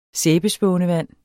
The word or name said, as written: sæbespånevand